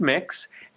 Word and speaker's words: MYX: First off, it's pronounced Mix.